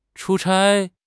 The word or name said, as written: chu1 chai1